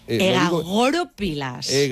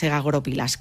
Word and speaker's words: egagrópilas: Egagoropilas. – Vamos a poner el acento en la O.